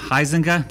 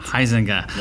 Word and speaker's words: Huizenga: I'm very excited about our guest today, Dr. Jessica Heisinger. Did I pronounce that right? Perfect. Heisinger.